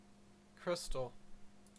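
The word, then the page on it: crystal: Canadian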